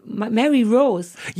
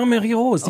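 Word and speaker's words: Mary Roos: Nee, die andere Frau, die neulich auch bei Sing Mein Song war, die Mary Rose. – Ja, Mary Rose. Oh. – Man darf das nicht englisch aussprechen. Mary muss man sagen, ne? – Ja, aber wie wenn ich Rose.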